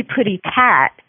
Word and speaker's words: Putty Tat: Putty Cat, but it's spelled funky. Tell me about Putty Cat. – Oh, it's actually Putty Tat. And oh, Putty Tat. – Yeah, that's the pronunciation from the cartoon.